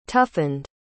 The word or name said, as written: toughened